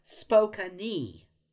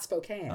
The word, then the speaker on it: Spokane: But it's pronounced, apparently, Spokane, not Spokane.